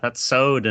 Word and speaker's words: Souad: I said it was Soad, but I probably am way fucking off. S-O-U-A-D. – Like System of a Down? Like sure. – Soad? – We'll go with that. I'm yeah, the U I'm not willing to pronounce. – The U? Just Soad. Yeah. Just Soad, man.